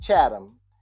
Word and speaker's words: Chatham: If you go on to Google, it's the town I live in, which is the country area, is pronounced Chatham, and that's spelled C-H-A-T-H-A-M as in Mary.